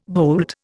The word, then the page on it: boult: Indian